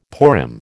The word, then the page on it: Purim: American English